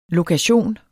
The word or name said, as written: LOKATIONER